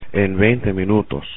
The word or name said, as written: en 20 minutos